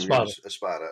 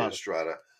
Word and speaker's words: Espada: Espada. – I was going to say Estrada. Espada, I like his style.